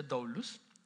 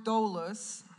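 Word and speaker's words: doulos (δοῦλος): Es la palabra doulos, así se pronuncia doulos.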